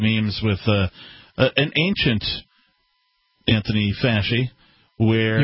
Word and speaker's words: Fauci: No, it's Anthony Fasci. That's the correct pronunciation.